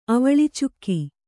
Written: ಅವಳಿಚುಕ್ಕಿ

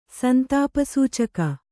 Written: ಸಂತಾಪಸೂಚಕ

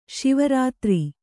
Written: ಶಿವರಾತ್ರೆ

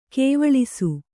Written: ಗಾವಳಿಸು

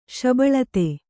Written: ಶಬಳತೆ

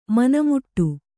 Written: ಮನೆಮುಟ್ಟು

